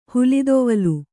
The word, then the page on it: ಹುಲಿದೊವಲು: Kannada